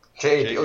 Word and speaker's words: jape: Jape. – J-A-P-E? – Yeah. – J-A-P. – Jape. – Oh, Jap. No, I guess it's pronounced Jape. I actually didn't listen to the pronunciation. – J-A-P.